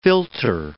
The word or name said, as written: philter